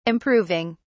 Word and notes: improving: British English

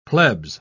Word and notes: plebs: British English

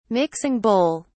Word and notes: mixing bowl: British English

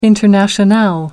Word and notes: internationale: American English

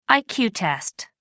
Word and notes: iq test: American English

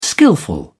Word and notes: skilful: British English

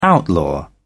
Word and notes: outlaw: British English

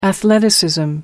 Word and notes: athleticism: American English

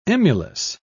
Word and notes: emulous: British English

